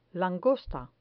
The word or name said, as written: langosta